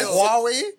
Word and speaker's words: Huawei: Like Huawei. – Huawei. That's how you pronounce it.